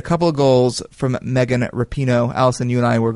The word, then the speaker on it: Rapinoe: Good emphasis on the Rapinoe, not Rapinoe. – Rapinoe. Okay. So behind a couple of goals from Megan Rapinoe